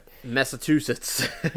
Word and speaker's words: Massachusetts: I Massachusetts. Nej, jag kan inte uttala det. – Massachusetts.